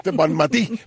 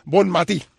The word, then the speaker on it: Bonmatí: Y fue una catalana que ya es campeona del mundo con la selección española, Basmati. Bonmatí, perdón, tengo que pronunciarlo como catalán. Bonmatí.